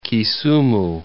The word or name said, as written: Kisumu